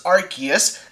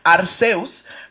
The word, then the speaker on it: Arceus: Salió Pokémon Legends Arceus, así es que se pronuncia en inglés por si acaso. Sé que en japonés y en español es Arceus, okay.